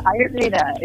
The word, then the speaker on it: Ayurveda: You practice something called Ayurveda. Did I pronounce that correctly? – Yeah. – All right. Yeah. All right. All right. – So, yeah, you did good. Ayurveda is that's the way I say it.